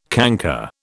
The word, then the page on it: canker: British English